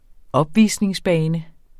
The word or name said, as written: opvisningsbane